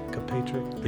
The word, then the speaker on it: Kaepernick: Kaepernick.